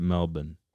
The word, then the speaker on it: Melbourne: So you sure it's Melbourne, not Melbourne? – No, Australians pronounce it Melbourne.